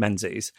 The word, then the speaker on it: Menzies: That's one of those names that I've got it in my head that you might pronounce it Mingus. – What? – I don't know. I think it's a Scottish thing.